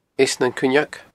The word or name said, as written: Eas nan Cuinneag